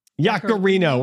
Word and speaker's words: Yaccarino: Yaccarino. – We're going to go with Yaccarino, according to apologies.